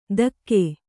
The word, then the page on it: ಧಕ್ಕೆ: kannada